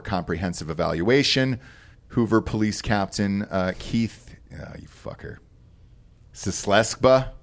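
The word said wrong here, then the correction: Czeskleba: how are we doing that, Cheskleba. – C-Z-E-S-K. – Cheskleba is how you say that.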